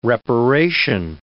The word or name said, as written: reparation